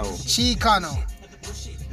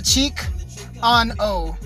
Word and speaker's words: Chicano: Chicano rap. That's right, fuckers. Chicano rap. Fuck. – Chicano. – Chicano. Not cheek on O. Not like cheek on O, but chic.